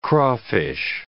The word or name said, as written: crawfish